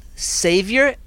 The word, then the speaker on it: Saiver: It will take a couple years, but right now they do have a brand called Savior, S-A-I-V-E-R, it's spelt, and they're trying to get into the market, but they can't get in.